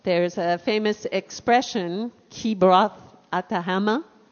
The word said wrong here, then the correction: Hatahava: Atahava. – Atahava.